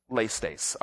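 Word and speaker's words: laistes (λῃστής): The Greek word underlying that in the New Testament, it's pronounced laistes, all right?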